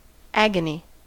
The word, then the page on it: agony: American English